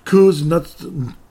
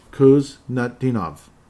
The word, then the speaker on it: Kuznetsov: So 37, ultimately, Merat Kuznetsov. That's how it is pronounced. Pardon me for slipping around. When you look at the name, it's kind of intimidating. But if you sound it out, it makes sense. Kuznetsov.